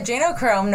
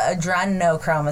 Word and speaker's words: adrenochrome: Because I say adenochrome, no problem, but adrenochrome is how you say it. Just think adrenaline.